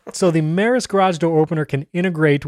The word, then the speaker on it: Meross: And so I got the Meross, is how you correctly pronounce it, Meross.